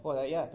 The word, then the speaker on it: Joiada: Pero si vemos en Segunda Reyes, y no quiero leer porque es mucho material que tengo que enseñar hoy, Joiada empieza, el sacerdote Joiada se pronuncia, Joiada, perdón, quiere seguir la voluntad de Dios y todo,